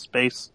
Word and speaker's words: Spass: His last name is spelled S-P-A-S-S, but he corrects everybody that says Spass. He's like, it's pronounced Space.